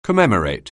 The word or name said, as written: commemorate